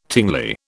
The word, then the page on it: tingly: British English